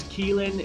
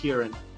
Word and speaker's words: Kieran: They do that thing that you said in the subtitles, where it's like the Krillin or they put it as Keelin. It's pronounced Kieran.